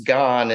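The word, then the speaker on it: gone (癌): And he wrote the character for cancer on the whiteboard and said it's pronounced gone and means, you know, obviously cancer.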